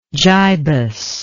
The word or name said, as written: gibus